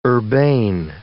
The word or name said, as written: urbane